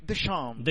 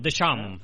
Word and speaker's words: Deschamps: Però no diguis Deschamps, digues com ha dit José Luis Gil, que diu Deschamps. – Deschamps.